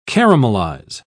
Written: caramelize

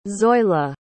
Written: zoila

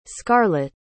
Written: scarlotte

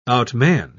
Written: outman

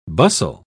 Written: bustle

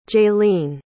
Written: jayleen

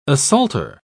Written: assaulter

